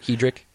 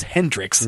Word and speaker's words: Hedrick: Like Hedrick is, I think, how he prefers it. – I had him on last week, and I butchered his name. I thought his name was Hendrix.